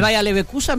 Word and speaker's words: Bayer Leverkusen: Al Bayer Leverkusen, me encanta. – Lo dijiste muy bien. – Al Bayern Múnich, vos preferís ver a todos esos. – Al Bayern München y el Bayer Leverkusen.